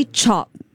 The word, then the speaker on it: chop: It's actually chop. – But mispronounced. – We've been saying chope this whole time.